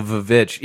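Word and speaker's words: VVitch: I feel only, because I pronounce it the witch. I say the witch, guys. – Yeah, come but on. There are people out there who are like, oh, yeah, the Vavitch.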